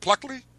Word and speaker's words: Pluckley: Is that how you say it? Pluckley? – Yeah.